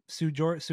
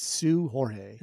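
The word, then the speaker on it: Seu Jorge: It's funny, but it's actually pronounced Sway Jorge. And so I was like, okay, I get that possibly. But then I looked it up this week. I think it's Sue Jorge. – Is it Sue Jorge? – I don't think the S-E-U is pronounced Sway.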